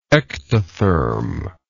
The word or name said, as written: ectothermal